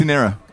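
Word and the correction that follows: quinceañera: Kintanera Quinceanera, yeah.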